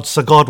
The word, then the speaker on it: Psagot: we will certainly have more details about Sagot Winery. We'll have their website listed and other important information for you to follow. So definitely go to your search engines, look for Sagot Winery. It's spelled P-S-A-G-O-T Winery, or come on to America's Roundtable Radio.